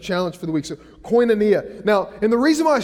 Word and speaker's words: koinonia: Uh, this is this word for fellowship or partnership, and it's, and it's pronounced like this koinonia. Everybody said that with me koinonia.